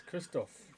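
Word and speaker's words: Krzysztof: So let's start with Maris Bradis and how do you say his first name? – Glowacki. – Yeah, I know his second name. – It's Christoph. – Is it? – Yeah, Christoph Glowacki.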